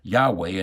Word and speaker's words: Yahweh: Now, from these words, "I am," are derived the name of God in the Old Testament that some people pronounce Yahweh and other people pronounce Jehovah.